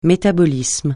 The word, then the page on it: métabolisme: francais